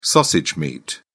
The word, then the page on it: sausage meat: British English